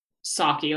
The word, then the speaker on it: Psaki: Psaki, like a silent P.